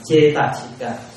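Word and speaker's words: cetasika: Chữ này đọc là Chetasika.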